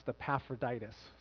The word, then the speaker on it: Epaphroditus: We'll come to a difficult name, and I want you to know that's pronounced Epaphroditus.